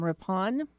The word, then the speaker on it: Rippon: His name is Mr. Adam Rippon. Is that how you say it? – That's it.